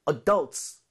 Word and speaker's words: adults: Adults. 강세 주의해 주시고요.